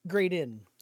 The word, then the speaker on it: Graydon: Grayson. Is that how we pronounce it? – Graydin.